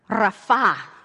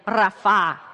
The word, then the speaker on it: rapha: Still is the Hebrew word rapha. Can you say that with me? Roll your R and everything. Rapha.